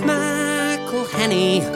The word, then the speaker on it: McElhenny: Pronouncing all those N's and E's and H's can perplex them. So here's a little birthday gift from all your mates in Wrexham. It's McElhenny, McElhenny.